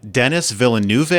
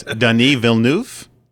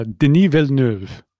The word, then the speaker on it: Denis Villeneuve: So we're going to talk about the movie Chocolat, the 2000 film, and Sicario from 2015 by Denis Villeneuve, I think. Is that how you say it? No, it's Denis Villeneuve. – Yeah, Denis Villeneuve.